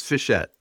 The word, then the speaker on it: Fischette: Almost. It's Fischette, like a small Fischette.